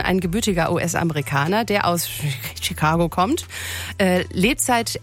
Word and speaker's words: Chicago: Obwohl normalerweise CH auf Englisch CH ausgesprochen wird, eigentlich ist es Chicago, als ob es SCH wäre.